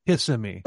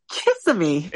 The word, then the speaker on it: Kissimmee: Kissimmee is the big one because people want to pronounce it Kissimmee. – It's Kissimmee.